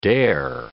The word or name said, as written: dare